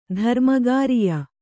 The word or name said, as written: ಧರ್ಮಕಾರ್ಯ